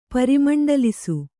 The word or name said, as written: ಪರಿಮಂಡಲಿಸು